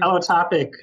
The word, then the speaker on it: allotopic: So I was curious about alloptic, if I'm pronouncing that right, expression. – Allotopic, we usually say. – Allotopic, thank you. Allotopic expression.